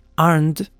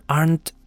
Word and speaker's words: Arndt: Esto se sumó con una acusación de parte de la detective Linda Arndt, a quien mencionamos en el episodio anterior, Linda Arndt, en caso de que tengan dudas de cómo estoy pronunciando el apellido, es A-R-D-N-T, Arndt, con una T final, Arndt,